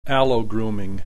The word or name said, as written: allogrooming